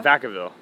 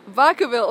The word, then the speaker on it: Vacaville: So you were born in, how do you say it, like Brackettville? In California? – In California, yeah. Vacaville. – Vacaville, oh my god. – It translates to Cowtown in Spanish.